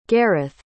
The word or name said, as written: gareth